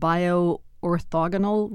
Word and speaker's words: bio-orthogonal: And how do you say this word? Bio-orthogonal reactions? – You got it.